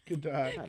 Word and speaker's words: Gadot: Godot. It's like Merlot. It's Merlot.